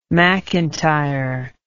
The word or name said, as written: mcintyre